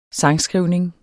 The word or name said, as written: sangskrivning